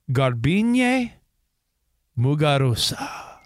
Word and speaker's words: Garbiñe Muguruza: Yeah, let's hear you pronounce her name. – In a Grand Slam. And her name is Garbiñe Muguruza. – You got it.